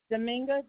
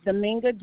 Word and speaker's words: Dominga: Dominga D'Souza. – All right, can you spell that for me? – D-E-S-O-U. Z-A is the last name. And the first name is Dominga, D-O-M-I-N-G-A.